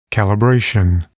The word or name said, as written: calibration